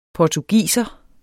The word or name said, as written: portugiser